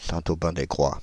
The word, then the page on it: Saint-Aubin-des-Grois: Paris